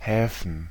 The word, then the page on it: Häfen: /ˈhɛːfɱ̩/; /ˈhɛːfən/